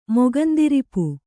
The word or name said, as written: ಮೊಗಂದಿರಿಪು